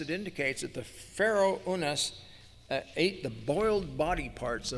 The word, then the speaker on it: Unas: It may correspond to the name Winnis, or usually pronounced Unas, by those who transliterate it from the Egyptian.